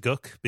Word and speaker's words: Gook: It's called Gook, although it is written out as though it was a slur. I know that it is pronounced Gook because I listen. The audiobook is narrated by Stephen King, so you get the actual pronunciation.